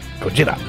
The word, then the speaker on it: Gojira: Gojira.